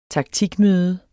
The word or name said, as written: taktikmøde